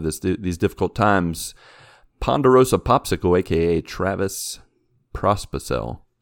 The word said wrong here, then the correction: Pospicel: Did I say that right? – Prospicel? – Nailed it. – Nope, there's no R right there. – Pospicel.